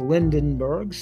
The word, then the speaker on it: Lindeberg: I would say last name's pronounced Lindenberg, spelt L I N D E B E R G, studied the inhabitants of, spelt K I T A V A.